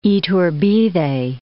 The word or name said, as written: Iturbide